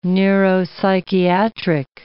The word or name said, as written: neuropsychiatric